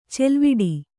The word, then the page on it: ಚಲವಿಡಿ: calaviḍi